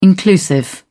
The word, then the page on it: inclusive: American English